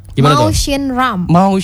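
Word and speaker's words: Mawsynram: Nah, Masrenram bukan tuh satu kota-kota yang punya curah hujan tinggi. Tapi gua tahu nih cara bacanya. – Mao Shin Ram. Yes, thank you.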